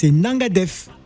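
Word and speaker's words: Nanga def: Ah, c'est quoi le mot en wolof que vous connaissez euh, Nangodef Ah non, non, c'est Nangadef.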